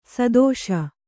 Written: ಸದಸ್ಸು

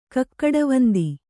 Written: ಕಕ್ಕಡವಂದಿ

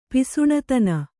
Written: ಪಿಸುಣತನ

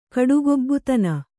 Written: ಕಡುಗೊಬ್ಬುತನ

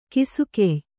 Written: ಗಾಸಿಗೆಯ್

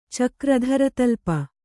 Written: ಚಕ್ರಧರತಲ್ಪ

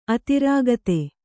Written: ಅತಿರಾಗತೆ

